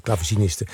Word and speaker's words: clavecinisten: Het tweede deel uit het vijfde Brandenburgsconcert van Bach met Les Arts Florissants onder leiding van Emmanuel Rachet-Caserta en de clavecinisten, zeg ik dat goed? Dat zeg ik goed, clavecinisten, was Béatrice Martin.